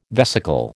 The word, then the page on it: vesicle: American English